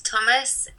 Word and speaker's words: Thomas: Okay, it's Kate Thomas, K-A-T-E, followed by Thomas, T-H-O-M-A-S.